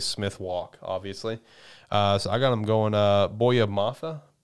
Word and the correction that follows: Boye Mafe: I could be wrong, but I think it could be Boye. – Boye Mafe.